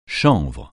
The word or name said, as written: chanvre